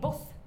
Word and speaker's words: Bosch: Hieronymus Bosch. Nej, Boss. Fick jag veta när jag var där att det är så man uttalar det. Fast det stavas inte så.